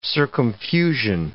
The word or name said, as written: circumfusion